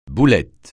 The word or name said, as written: boulette